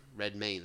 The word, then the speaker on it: Redmayne: Redmayne, that's how you say it.